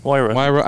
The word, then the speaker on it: Huayra: It was when, and I can never pronounce the name, but the Pagani Huayra, is that? – Huayra.